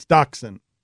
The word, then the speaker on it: dachshund: Is it pronounced Dachshund? – I think it's Dachshund. – It's Dachshund.